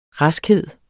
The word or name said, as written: raskhed